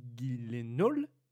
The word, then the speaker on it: Gyllenhaal: On a d'abord eu Jake Gyllenhaal, je l'ai bien prononcé, Joshua Jackson, qui était envisagé Kylian Murphy.